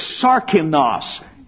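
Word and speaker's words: sarkinos (σάρκινος): The word is sarkinos. S-A-R-K-I-N-O-S. In the third verse, there's another word for carnal that is almost like that. But that word is spelled like this. Sarkikos, S-A-R-K-I-K-O-S. Sarkinos, sarkikos. Two different words.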